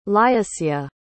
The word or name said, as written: lyusya